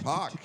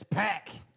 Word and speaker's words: PAC: Which Pac came in like a. Came in like a beast. – It's Pack. – Yeah, Pack. Yeah, which that's officially, it's actually Pack. That is how you pronounce it.